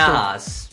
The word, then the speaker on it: Nahas: Con allora, una cosa che volevo dire su questa cantante Nahas, credo che si pronunci così, come sì. – L'hai scritto tu, come l'hai letto? – Nahas. – Nahas. Ecco, devo impararlo, perché sennò se mette un'altra canzone fuori non lo so pronunciare.